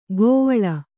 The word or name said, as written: ಕೋವಳ